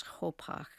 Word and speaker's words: Gopak: Actually, it's from a larger piece from his opera Mazeppa, a dance called the, well, it's spelled G-O-P-A-K, Gopak. Sometimes you hear it as Hopak, and it's from a verb meaning to jump.